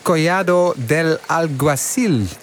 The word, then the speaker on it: Collado del Alguacil: Het is een loodzware bergetappe van bijna 187 kilometer van, moet ik het in mijn beste Spaans zeggen, La Calahorra na Collado del Alguacil. Toch, wielercommentator Andries Lamin? – Ja, toch. Als in dat ik eventjes die uitspraak beoordeel. Nou, helemaal goed, helemaal goed.